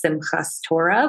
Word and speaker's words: Simchat Torah: And then right after that is the holiday of Simchat Torah, or people might pronounce it Simchas Torah, which is a celebration of reading the Torah or the Old Testament on the scroll.